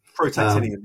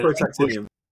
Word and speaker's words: protactinium: Protactinium, yeah. That's very good. – I've got to step in as the chemist in the room. – please do, because I can't pronounce it. Yes. – Yeah, protactinium.